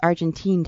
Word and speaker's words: Argentine: And, you know, I thought I'd start by asking you, Stephen, how long have you been doing Argentinian tango? – I've been dancing Argentinian tango for about 8 years now. – Oh, good. So I'm mispronouncing it. It's Argentine tango. Or is it Argentine? – Right.